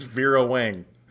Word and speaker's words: Vera Wang: It's Vera Wang.